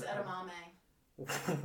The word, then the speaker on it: edamame: Atabombes are terrible, and they're not even atabombes. – He means edamame.